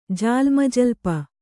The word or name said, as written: ಜಾಲ್ಮಜಲ್ಪ